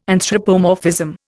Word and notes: anthropomorphism: Indian

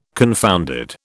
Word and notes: confounded: British English

